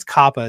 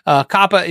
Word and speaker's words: COPPA: I think it's COPPA is how they say it.